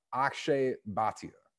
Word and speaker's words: Akshay Bhatia: Akshay Bhatia.